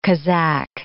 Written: Kazakh

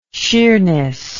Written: sheerness

